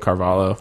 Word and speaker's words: Carvalho: So the main event, Gegard versus Caballo. – We were butchering it, but we'll just go with Carvalho.